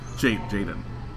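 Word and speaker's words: Jaeden: The kid, Jadeen Martell. – Jaden. – Yeah. – Wow. – The kid from It. – Jadeen? I'm looking at it. It looks like Jaden. – It's Jaden. It's just J-A-E, which is weird.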